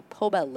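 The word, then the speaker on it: Poma: They had a rope tow and eventually got a POBA lift. Did I pronounce that correctly? – Sure.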